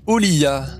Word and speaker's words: Olija: donc Oliya, euh, Olia, donc qui s'écrit Olija pour ceux qui voudront euh, euh, chercher le jeu.